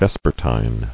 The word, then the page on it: Vespertinal: Ves"per*ti`nal